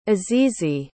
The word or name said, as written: azizi